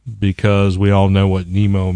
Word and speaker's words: Nimmo: I don't know if you pronounce it Nemo, N-I-M-M-O.